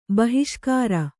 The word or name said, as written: ಬಹಿಷ್ಕಾರ